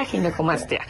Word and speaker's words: ginecomastia: Para empezar, la palabra, le decía, ¿cómo se pronuncia? ¿Ginecomastía? Ginecomastia. – Sí, exacto. Es ginecomastia.